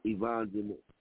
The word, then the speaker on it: Yvonne Dene: I mean, you could do it with me or someone like, I wrote her name down, Eva Dene. Am I pronouncing her name right? Eva Dene. Is that her? – Yvonne Dene. It took me a whole show to learn how to pronounce her name.